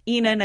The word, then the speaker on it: Ihnen: my last name is Ihnen and it's spelled i-h-n-e-n so at mezzo Ihnen and that brings me to number two count and clap.